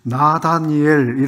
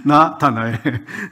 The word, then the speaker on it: 나다나엘: 나다니엘 이렇게 발음을 하는 거예요. 나다니엘, 나다나엘이죠. 그래서 나다니엘, 예, 이렇게 하면 되겠구나 이런 생각을 했습니다. 나다니엘, 아니 나다나엘 죄송합니다. 나다나엘.